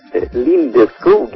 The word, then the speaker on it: Lindeskog: Lindeskog in Swedish. But I say Lindeskog, I like that. It's so cute. – In English, Lindeskog.